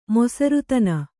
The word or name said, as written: ಮೊಸರುತನ